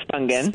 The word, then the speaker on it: Spungen: No, with a hard G, Susan Spungen.